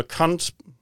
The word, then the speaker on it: Kant: And it is Immanuel Kant, unfortunate name in English, but spelled with a K-A-N-T.